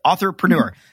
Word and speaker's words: Authorpreneur: Authorpreneur.